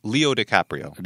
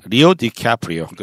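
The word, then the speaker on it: Leo DiCaprio: 레오나르도 디카프리오가 아니고 발음은 리나르도 디카 디카프리오? 어, 그냥 리오 디카프리오. 리오 디카프리오. 그렇게 음, 음. 발음하는군요. 네, 알겠습니다.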